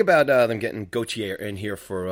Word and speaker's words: Gauthier: How do you say his name, KD? – I think it's Gauthier. – Gauthier. All right. Yeah, that sounds about right.